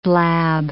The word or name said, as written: blab